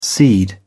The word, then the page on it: seed: British English